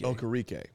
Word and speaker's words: Okereke: but how do you say that guy's name? – Okereke? – Bobby Okereke. – Okereke.